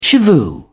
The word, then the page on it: shivoo: British English